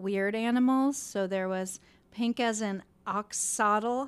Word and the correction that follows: Axolotl: How do you say that? – Axolotl.